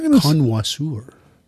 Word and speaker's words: connoisseur: She says, my question though, to cocktail connoisseur, are, should I say connoisseur or connoisseur? – I was just going to say, as an aside, did you hear how he pronounced that? Connoisseur. – Yes. – I believe it's connoisseur, but I think it is connoisseur.